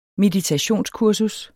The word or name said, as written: meditationskursus